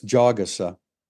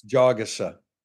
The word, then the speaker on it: Jagasa: We keep saying, you know, we're pronouncing his name wrong. It's Jagasa. That's like the fourth pronunciation we've had on his name. But were you there when he clarified? – Yeah, it's Jag. I remember the word jog.